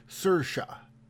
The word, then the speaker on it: Saoirse: Doesn't even have a Sir Sir Sir Shay? Sir She. Oh my god. do it again. I'm gonna have to get, Google the pronunciation of her name. It's pronounced Sersha.